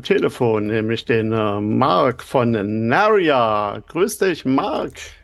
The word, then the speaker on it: Naria: Ich persönlich sehe es als für mich ist es ein Mädchenname und ich selber spreche es als Naria aus, also mit Betonung auf die zweite Silbe.